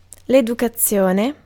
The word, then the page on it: manners: American English; /ˈmæn.ɚz/